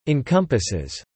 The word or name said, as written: encompasses